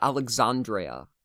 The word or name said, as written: Alexandreia